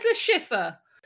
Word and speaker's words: Le Chiffre: Just Le Chiffre. – It yeah. says Le Chiffre. – It's French.